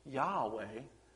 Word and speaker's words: Yahweh: So as Hayah means to exist or to be in existence, YHWH, commonly pronounced Yahweh, means the existing one.